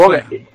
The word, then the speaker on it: Vogue: Porque como se escribe de una forma un poco extraña, por eso he dudado. ¿Bogue o Boge? No sé. – Perdón, he dudado. Bueno, pues Boge.